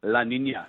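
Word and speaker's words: La Niña: Your pronunciation is exceptional. Say it again. – La Nina.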